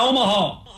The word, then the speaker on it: Omaha: We might need you in Omaha. – Coach, one more time. It's kind of three-syllable. It's rhythmic, right perfect. From the diaphragm.